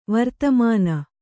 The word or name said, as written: ವರ್ತಮಾನ